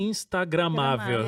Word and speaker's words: Instagramável: Instamagrável. – Não. – Instagramável. – É. – Gente, não sai a palavra. Falei certo? – Instagramável. – Instagramável. Isso. Saiu.